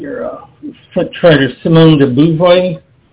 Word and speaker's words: Beauvoir: Yes, Simone de Beauvoir, B-E-A-U-V-O-I-R. – Oh, Simone de Beauvoir, Simone de Beauvoir, Beauvoir. – Yes. – Yeah. Yes, thank you. – Yes. – Thank you for saying it right.